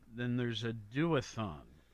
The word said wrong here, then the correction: duathlon: Duathlon. How do you say that? – It's pronounced duathlon, and that happens simultaneously with the triathlon.